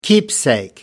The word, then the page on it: keepsake: American English